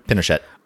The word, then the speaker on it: Pinochet: March 2, the former Chilean dictator General Augusto Pinochet, I don't know how to pronounce that. – Pinochet.